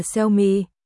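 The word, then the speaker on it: Xiaomi (小米): Tiêu Mật, Xiaomi, phát âm là Xiaomi.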